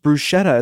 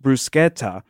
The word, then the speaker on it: bruschetta: For example, it's not pronounced bruschetta, it's pronounced bruschetta.